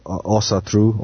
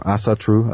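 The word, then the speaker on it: Ásatrú: Nowadays, we have the rising of Asatru, or Asatru, as it would be pronounced in English, which is, again, more of a Nordic, you know, countries like Iceland and Norway and Sweden.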